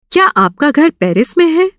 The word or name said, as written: में